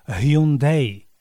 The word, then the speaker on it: Hyundai: Hlasové ovládanie mnohých smartfónov nepovie Hyundai alebo Hyundai, ale znie to ako die, čo v preklade znamená ahoj a zomri. die. No, alebo sa Hyundai vyslovuje aj ako Eye. No, čo je takisto metúce. Žena na konci veselej reklamy vysloví všetko správne ako Hyundai.